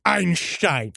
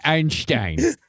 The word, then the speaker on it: Einstein: Can we use this series to correct? It's Einstein. – Okay, Einstein. Albert Einstein. – Einstein. – Does that make you feel better if I say it like that? – Yes.